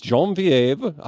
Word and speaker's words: Genevieve: Yes, Genevieve.